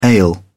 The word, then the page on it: ale: British English